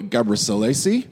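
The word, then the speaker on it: Gebrselassie: Another one that we got sent in is a 10,000-meter runner, Hale Gabrielassi. – Yep. Well pronounced.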